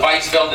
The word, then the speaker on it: Weinfeld: Sorry, Weinfeld. I spell it Weinfeld, it's Weinfeld.